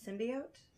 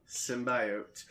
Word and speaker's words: symbiote: And then the whole internet's like, they don't know how to pronounce symbiote. – So... I'm okay with symbiote. I'm okay with symbiote. But symbiote, that's too many long vowel sounds. That doesn't make any sense.